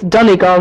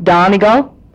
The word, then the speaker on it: Donegal: and Glenties would be pronounced Glenties because it's G-L-E-N-T-I-E-S, and it's Donegal, not Donegal, but that's okay. You can call it Donegal if you want.